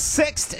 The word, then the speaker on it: Sixt: It's pronounced Sixt. It is pronounced Sixt in German, starting with a Z sound.